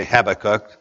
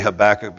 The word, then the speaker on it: Habakkuk: I realize that our pronunciation is not the same. I realize some people say Habakkuk. I say Habakkuk because I was trained to preach by a southern preacher.